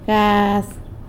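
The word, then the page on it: gaz: San Lucas Quiaviní Zapotec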